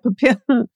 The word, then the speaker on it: Papillon: Papillon. – Papillon. Thank you. I knew that the LL was not a hard L, but I couldn't remember.